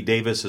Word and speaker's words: Daviess: And for those folks listening outside of Kentucky, Davis is spelled D-A-V-I-E-S-S. – That's right. Yeah. Not Davies.